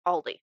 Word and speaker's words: Aldi: But I'm aware that it's pronounced differently in other countries. But yes. in the U.S., it is pronounced Aldi. – Ah. – So, because I've seen we have people from Germany, and mm-hmm. I believe they pronounce it differently yeah.